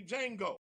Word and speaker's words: Django: I'm sure all of you, if not, go check it out, have seen the movie Django. D, capital D, J-A-N-G-O. The D is silent.